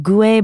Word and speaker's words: Gouais: The parents of Chardonnay are Pinot Noir, the red wine grape, and a grape called Gouais Blanc. That's spelled G-O-U-A-I-S.